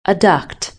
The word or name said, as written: Adduct